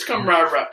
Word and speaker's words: camaraderie: No, it's camaraderie. As many R's as you can fit.